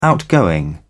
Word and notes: outgoing: British English

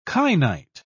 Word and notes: kainite: British English

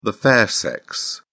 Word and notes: fair sex: British English

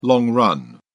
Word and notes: long run: British English

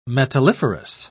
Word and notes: metalliferous: British English

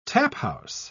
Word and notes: taphouse: British English

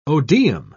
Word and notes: odeum: British English

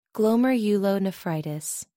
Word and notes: glomerulonephritis: American English

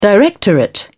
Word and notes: directorate: British English

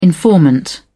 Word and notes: informant: British English

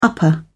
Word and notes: upper: British English

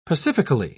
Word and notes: pacifically: British English

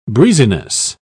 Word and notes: breeziness: British English

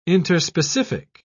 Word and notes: interspecific: British English

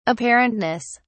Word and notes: apparentness: British English

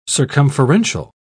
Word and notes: circumferential: British English

